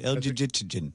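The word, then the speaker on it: Elgygytgyn: I've never really known how to pronounce El Jijitjin. How would you say this? – Yeah, I think you had it right. El Jijitjin.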